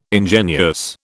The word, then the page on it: ingenuous: British English